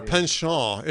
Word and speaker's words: penchant: Or penchant, if you really want to, get into the French pronunciation, the penchant for embellishment.